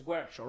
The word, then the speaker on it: Guercio: And I've seen even kids I went to school with, like for example, my last name, the correct pronunciation Guercio. – Is Guercio, right?